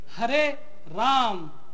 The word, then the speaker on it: Hare Ram: He said, now my question to you is, the correct pronunciation is Hare Ram. But you have been chanting this mantra, Hare Ramo, Hare Ramo, Ramo Ramo, Hare Hare.